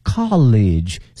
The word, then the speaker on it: college: with his colleague 后面这个是表示同事啊， colleague C-O-L-L-E-A-G-U-E colleague 这个词啊往往和我们同学学过的这个大学经常的弄混，大学呢是 college C-O-L-L-E-G-E，而这个呢是 C-O-L-L-E-A-G-U-E，发音不一样哈。